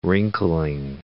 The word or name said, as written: wrinkling